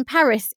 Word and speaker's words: Paris: Emily in Paris is supposed to be pronounced with a French accent. So Emily and Paris rhyme.